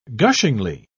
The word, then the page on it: gushingly: British English